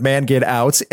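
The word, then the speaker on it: Mangetout: Wet Legs, Man Get Out, which I badly mispronounced in the video I made because it's all one word, man get out. I thought it was like manga too. I thought it was French. It's not.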